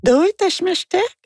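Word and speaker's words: Doitashmashite (どういたしまして): Non, je prononce pas correctement. Elle dit non, il faut dire Doitashmashite.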